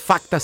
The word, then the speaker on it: Facta: Facta se escreve com um C mudo no meio do caminho, F-A-C-T-A.